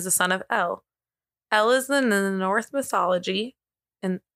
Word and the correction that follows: Norse: It sounds like I said North. I have really bad pronunciation. Norse mythology, who is originally the name of the dead, but later came to be the goddess of death itself.